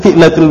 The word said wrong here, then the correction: fa'illatul: bina. Iya, benar. Fa'illatul bina.